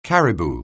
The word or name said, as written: caribou